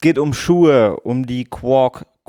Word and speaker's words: QUOC: Ich sag mal Quark. So, Quark. Quark. Quark. Q-U-O-C.